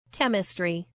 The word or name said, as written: chemistry